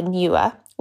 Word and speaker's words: NEWA: I'd recommend the Newer, which is spelled N-E-W-A.